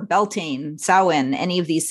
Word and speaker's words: Samhain: Like Leah said, it's not Samhain, even though I guess phonetically that might be how you'd say it. So the word Samhain is Gaelic in origin, and it's pronounced Samhain. So like S-O-W-W-I-N.